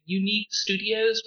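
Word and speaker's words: Youneek Studios: Like, if we're going to talk Afrofuturism comic art, comic books, I just want to send a shout-out to Unique Studios, which is spelled Y-O-U-N-E-E-K Studios.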